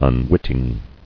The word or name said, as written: Unwitting